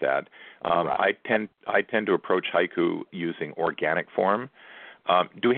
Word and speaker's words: haiku: The word haiku in English is two syllables. In Japan, it counts as three sounds, ha-i-ku.